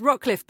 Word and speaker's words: Rocliffe: It's Rocliffe,